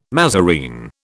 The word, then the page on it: mazarine: British English